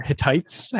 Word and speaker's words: Hittites: You can pronounce it Hitties or Hittites, however you'd like.